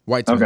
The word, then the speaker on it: Weitzman: It's either it's either Weitzman or Weitzman. What are, what are we feeling on that? Well, I'm saying Whites. Weitzman. Okay,